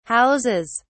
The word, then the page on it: houses: British English